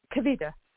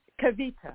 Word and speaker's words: Kavita: This is Kavita calling from New York. How are you? – Could you say your name again, please? – It's Kavita. – Tanika. – It's Kavita. – Oh. – K-A-V-I-T-A.